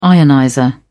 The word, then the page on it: ionizer: British English